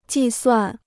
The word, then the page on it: 计算: jì suàn